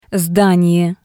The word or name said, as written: Sdanie